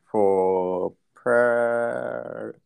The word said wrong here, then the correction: proprietor: proprietor. – Proprietor. Yep. – Proprietor. Yeah, definitely.